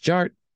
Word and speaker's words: Jart: The D is silent, so it's just Jart.